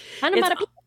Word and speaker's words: onomatopoeic: That is a word, and I looked it up, and I tried to say it right the first time. – Onomatopoeic. – Yes, but onomatopoeic. – The adjective, onomatopoeic. – These are words that sound, that are spelled like they sound. – Onomatopoeic. – Yes. Yes, right.